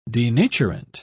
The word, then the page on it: denaturant: British English